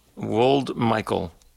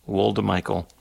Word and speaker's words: Woldemichael: From there, we're going to close this set off with music from the Ethiopian sax player, Girma, G-I-R-M-A, I think I might be pronouncing that right. Last name, Woldmichael, W-O-L-D-E-M-I-C-H-A-E-L, Woldmichael.